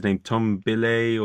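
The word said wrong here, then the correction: Bilyeu: He's Billio, yeah.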